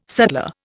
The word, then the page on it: sutler: Indian